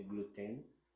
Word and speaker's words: gluten: uma euh, coisa importante do sarraceno é que ele não tem glúten. Aí ah, eu fiz um. cometi uma falta aí de pronúncia, né? Glúten, se pronuncia gluten.